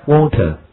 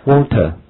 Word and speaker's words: water: Water. 啊，这个单词呢，在英国英语中这样来读，water, water。好，那美语中呢，美国人会怎么读呢？好，如果儿化音重一些的，water, water。这个如果你有美国朋友的话，经常这么来说，跟 me a bottle of water, water, water 这样来读的。